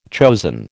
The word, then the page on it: chosen: American English